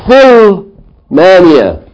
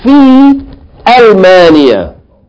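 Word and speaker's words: fi Almania (في ألمانيا): So you can't say fi. You can't say Fil Mania. You gotta say fi Almania.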